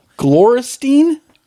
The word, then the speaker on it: Gloristine: Gloristine? – Gloristine. – What the fuck? – Spelled like Gloris Tine. T-I-N-E.